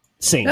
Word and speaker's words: Saint: like rat-a-tat train sequence with Ava Marie Sant, where it's like they're doing things that would have been considered, I'm sorry, I got her name wrong probably. I saw Saint, yeah. Not Sant.